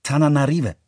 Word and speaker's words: Antananarivo: Antananarivo wird Tananarive ausgesprochen und wurde während der letzten hundert Jahre meistens auch genauso geschrieben.